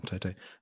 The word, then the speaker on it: Toto: Yeah, I think it was from Toto's interview, I said it right this time, Toto, that it was Valtteri's actual decision.